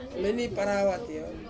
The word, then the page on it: meni parawati: Ansus